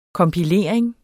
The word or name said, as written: kompilering